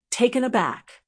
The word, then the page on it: taken aback: American English